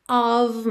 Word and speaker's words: of: Então, can't you feel the weight of my stare? De novo, esse of, OF com som de OV, You're so close, but still a world away.